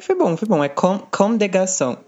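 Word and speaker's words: Comme des Garçons: Acho que é de É só o S do final que não pronuncia, do, do último, do D. É tipo Com de Garçon.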